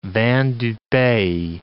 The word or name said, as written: vin du pays